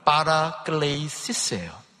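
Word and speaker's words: 파라클레이시스예요 (παράκλησις): 자, 발음을 좀 들어보세요. 보혜사는 파라클레이토스입니다. 위로라는 말은 파라클레이시스예요. 뒤에 토스와 시스 차이입니다.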